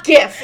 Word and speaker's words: GIF: Well, no, I think people who say JIF are, like, awful. They need to be lit on fire. – A plus. That's why we're friends. The only thing we agree on is GIF. It's a hard G.